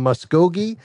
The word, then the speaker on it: Muskogee: Mike is in Muskogee, Oklahoma.